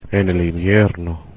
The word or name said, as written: en invierno